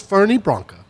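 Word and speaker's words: Fernet Branca: He says Fernie Branca. – Oh. – And so absolutely I just assume not. That's how it's pronounced, and nobody pronounces it that way.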